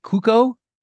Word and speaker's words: Cuoco: How do you pronounce Kaylee? Isn't it Cuoco? – Sure.